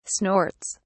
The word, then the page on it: snorts: British English